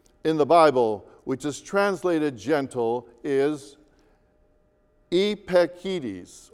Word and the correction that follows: epieikes: or epikes, actually. In English, we would spell it E-P-I-E-I-K-E-S, all right?